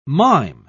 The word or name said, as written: mime